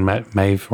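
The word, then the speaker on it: Medb: And when we talk about it, and obviously when people think about gender and the Táin, the inevitably Queen Maeve, or Medivh, as you pronounce it, comes up, and the idea that she's such a formidable female character, and obviously, and it's it was often pointed out.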